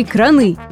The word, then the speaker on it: краны: Краны или краны? Итак, при образовании множественного числа ударение в случае с кранами падает исключительно и безоговорочно на первый слог. А ударение на последний является грубой ошибкой.